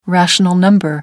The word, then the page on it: rational number: British English